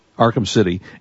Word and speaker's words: Arkham City: Arkham City.